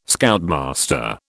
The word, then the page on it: Scoutmaster: British English